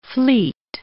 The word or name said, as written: fleet